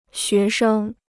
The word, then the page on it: 学生: xué sheng